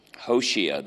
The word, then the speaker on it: Hosea: Hosea, that's his name. That's how they pronounce it in Israel, anyway, when they speak English to you, Hosea.